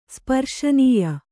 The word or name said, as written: ಸ್ಪರ್ಶನೀಯ